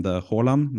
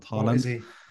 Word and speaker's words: Haaland: There's a pretty good one named Haaland, not Haaland.